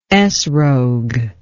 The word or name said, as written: ethrog